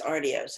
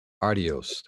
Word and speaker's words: Artios: And won the, I'm not sure how to say it, Artios Award for Best Location Casting in 2017 for Me and Earl and the Dying Girl. – Yes, it's Artios, actually, so.